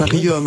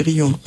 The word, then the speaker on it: Riom: Je crois qu'on prononce comme ça. C'est pas Riom, hein, c'est Rion.